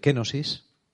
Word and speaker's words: kenosis: El quebrantamiento, lo que nosotros llamamos quebrantamiento, si lo dijéramos en términos más, teológicos, hablaríamos de, de kenosis, ¿eh? con acento en la E.